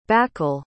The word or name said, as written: bakal